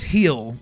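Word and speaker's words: Gil: Today, they're running Framber tomorrow against Luis Gill, who is or Luis Gill, I know that G is silent, or said as an H.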